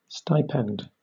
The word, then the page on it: stipend: British English